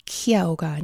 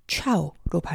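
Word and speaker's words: ciao: 라틴에서 백을 나타내는 centum, 캔텀은 이탈리아어에서는 C-E-N-T-O, 켄토가 아닌 첸토라고 발음합니다. 이탈리아어의 C-I-A-O는 키아오가 아닌 차오로 발음하죠.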